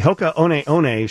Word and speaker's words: Hoka One One: The Hoka One One Chicago Half Marathon. – I'm sure that's how it's pronounced. – It is.